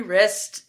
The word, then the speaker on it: Wriste: And the last name is spelled W-R-I-S-T-E. So Terry Wrist.